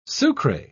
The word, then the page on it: sucre: British English